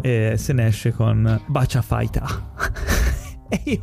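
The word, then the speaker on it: Bacia Fighter (バーチャファイター): La pronuncia della parola Virtua in tutta sua, in giapponese è scritto Bacia Fighter, quindi loro lo pronunciano Bacia Fighter, non in altro modo.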